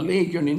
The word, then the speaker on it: Legion: Selle vastas võistkonna Legioni nime tuleb hääldada muide korrektselt, sellepärast, et kuna tegemist on Vene meeskonnaga ja Vene jalgpalluritega ja üldse see kõik see asi on puhas Vene värk, siis õige hääldus on Legion. Sellepärast, et vene keeles Legion kirjutatakse ühe eega, mitte kahega nagu Eestis.